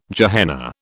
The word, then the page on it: Gehenna: British English